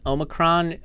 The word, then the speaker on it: Omicron: It's really nice to meet you, Omiki. Thank you so much for showing up. I'm really excited to connect. Thank you. – It's actually pronounced Omicron. It's Greek. It's a Greek name.